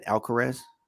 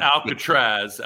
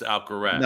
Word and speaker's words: Alcaraz: Is it Alcaraz? How do you say it? Alcaraz. Al. – Yeah, yeah. Al- the Spaniard. – Either way, he's. uh he's not Alcatraz, Alcaraz. – No, not not not Alcatraz.